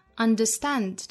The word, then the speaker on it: understand: Understand 这个词最后一个音节啊 understand. 在英音之中读成 and, 也就是英音之中的梅花 a, 上下开口度会更大发成 and,